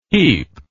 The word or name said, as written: heep